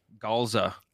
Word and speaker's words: Calza: Calza. – How do you pronounce the last name? Calza.